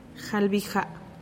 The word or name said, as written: jalbija